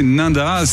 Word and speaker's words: Ninda: Vous avez fait en plus, nous a fait très plaisir parce que vous avez prononcé Ninda, ce qui en général n'est pas toujours juste. – Ouais, je peux vous le faire Ninda. – Ah, voilà, c'est vraiment Ninda. – C'est Ninda. – Ici à la locale, il faut vraiment le prononcer juste.